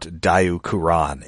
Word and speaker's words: Diaclone: And of course, I mean, we know what Diacuron is a reference to. – Yes. – For the uninitiated, that is the Japanese pronunciation of Diaclone, which was one of the series that seeded Transformers.